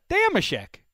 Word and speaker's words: Dameshek: can I ask a question yes how do you pronounce your last name my last name yeah Dameshek Say the say the last syllable. Sheck. Because the, the voiceover guy, it yeah. sounds like Schick.